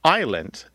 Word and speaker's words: island: So we don't pronounce the S in island because the French don't pronounce the S in aisle.